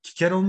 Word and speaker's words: Cicero: Kikero mu Çiçero mu? – Şimdi Latince'de C harfi K olarak okunuyor.